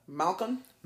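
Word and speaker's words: Malkoun: How do you say his last name? – Malcolm. – Malcolm? These names kill me.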